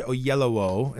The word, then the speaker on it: Oyelowo: This is David Oyelowo, is how you say his name.